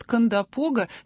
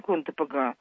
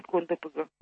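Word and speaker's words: Кондопога: Это финно-угорское название, и ударение в Карелии ставят на первом слоге. Железнодорожная служба тоже делает это ударение. Правильнее ударение Кондапога. Оно немножко необычно для нашего восприятия, но, в общем, правильнее Кондапога.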